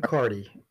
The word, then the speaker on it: Carty: Is it Carty? Is that how you say it? You got it just right. Austin Carty.